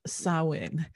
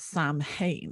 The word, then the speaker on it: Samhain: You may or may not know about this festival, and if you've seen it written down, you may think it is pronounced Samhain, because that is how it's spelt. It's S-A-M-H-A-I-N. But the pronunciation of it is Samhain or Samhain. There are different ways of pronouncing it depending on the Gaelic dialect.